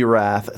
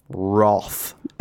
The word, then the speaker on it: Wrath: Because for those who don't live in Australia, Australians have this thing, maybe they do it in the UK, where they pronounce Wrath Roth. And it is insane. There's no, they don't spell it with an O. They don't add a W or something like that. Roth.